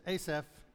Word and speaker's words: Asaph: How do you pronounce it? Go Asaph. So that's what it is, Asaph.